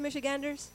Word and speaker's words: Michiganders: According to recent polling from Glengariff Group, nearly two-thirds of Michiganders, did I say that right, Michiganders? – Yeah.